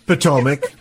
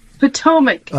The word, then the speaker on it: Potomac: How do you say that again? – Potomac. – Potomac.